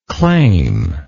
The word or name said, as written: claimless